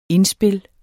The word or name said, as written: indspil